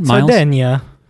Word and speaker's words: Sardegna: She's like, I already know, like, mom mentioned that that they met in Sardinia, which they later spell as Sardegna with a G, which I guess is how they spell it in original Italian. – Sardegna. – Thank you, for the original pronunciation.